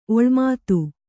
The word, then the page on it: ಒಳಮಾತು: oḷamātu